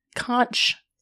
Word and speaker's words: conch: Conch. – No. – Isn't it conch? – No. – C-O-N-C-H, right? – It's spelled like that. – Yeah, but you, How do you pronounce it? H is silent.